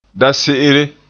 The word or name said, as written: dase'ere